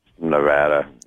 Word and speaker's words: Nevada: Is that how you say it, or is it Nevada there? – Nevada. Yeah. – I think I... it is Nevada.